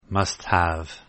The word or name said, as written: must have